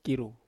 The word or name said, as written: kiru